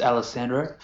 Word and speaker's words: Alessandro: Yes, my name is Alessandro, and Greg, thank you for having me on.